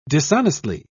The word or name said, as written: dishonestly